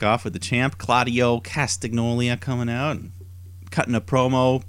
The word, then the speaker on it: Castagnoli: Castagnoli. I think it's really important that you kind of turn the G and the N into a N.